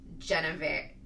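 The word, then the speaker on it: Genever: I'm going to go with Geneva. It's like believer.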